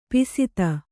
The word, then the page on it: ಪಸದ: kannada